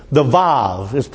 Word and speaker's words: vav: Then comes the vav. It's written W-A-W, but in Hebrew it's pronounced vav, V-A-V, which is a nail or a hook.